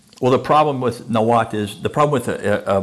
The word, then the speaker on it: Nahuatl: Nahuatl.